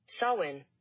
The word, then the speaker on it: Samhain: Again, it's spelled S-A-M-H-A-I-N, but it's Samhain. I'm going to do something here.